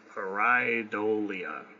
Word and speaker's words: pareidolia: It looks like pareidolia. – Close enough. – Okay. – Now, I think it's pronounced pareidolia, but...